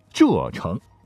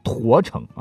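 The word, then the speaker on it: 柘城: 以及朱姓发源地浙城。木字旁旁边是一个石头的石，你不能读成驼城啊或踏城。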